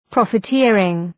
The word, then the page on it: profiteering: ‘prɒfı,tırıŋ